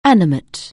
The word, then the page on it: animate: anglais